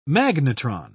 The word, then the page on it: magnetron: British English